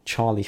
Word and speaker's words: Txarli: This is from Charlie Factory, spelt T-X-A-R-L-I, because I think you said they sounded a bit Basque, didn't you? – Yes, the T-X is pronounced the same as the C-H.